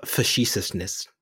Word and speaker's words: facetiousness: If I dial down the facetiousness, facetious. – Now I'm using fancy words just for the sake of it, but not pronouncing them correctly. Facetiousness. There we go.